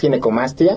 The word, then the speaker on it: ginecomastia: Pues muchas gracias y sí, definitivamente creo que es una, para empezar la palabra, le decía, ¿cómo se pronuncia? Ginecomastía, ginecomastia. – Sí, exacto. Es ginecomastia. Exactamente.